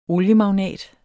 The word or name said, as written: oliemagnat